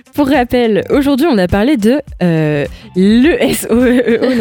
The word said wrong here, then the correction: ESEO: Non, j'ai comment on le prononce du coup ? – L'ESEO. – L'ESEO, parce que je le prononçais super mal.